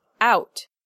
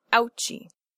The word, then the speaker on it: out: Out is one syllable with a stop consonant, not ouchie.